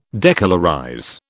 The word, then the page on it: decolorize: British English